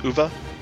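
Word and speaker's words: Uwe: Ooey balls? – W is a V. E is a schwa. So it's uva.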